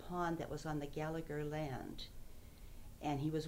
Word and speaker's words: Gallagher: Well, on the Gallagher side, and I guess I pronounced Gallagher wrong. There's no G-H. It's Gallagher.